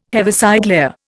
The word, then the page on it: Heaviside layer: Indian